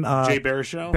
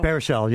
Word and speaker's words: Baruchel: Jay Baruchel? – Baruchel, yeah.